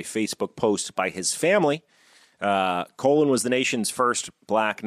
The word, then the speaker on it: Colin: he demanded people call him Colin. Even though his name is spelled exactly the same as the name Colin, right. C-O-L-I-N, he stuck with his Jamaican parents' pronunciation of his name,